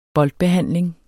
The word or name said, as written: boldbehandling